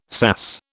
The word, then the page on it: SAS: British English